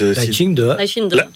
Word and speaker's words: Lai Ching-te: C'est finalement le candidat Lai Ching-te. Comment on prononce vous qui êtes Lai Ching-de. – D'accord. Très bien, du Parti démocrate progressiste.